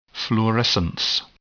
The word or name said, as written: fluorescence